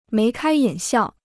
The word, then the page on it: 眉开眼笑: méi kāi yǎn xiào